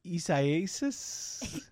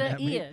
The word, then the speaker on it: Isaias: Isaias? – Isaias. – I see what you're doing. You're laughing Isaias.